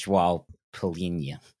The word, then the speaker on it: João Palhinha: Well, I'm sure there's going to be plenty of snark for me, but I think it's João Palhinha.